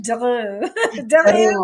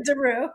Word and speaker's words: DeRieux: DeRieux or DeRieux, however you want.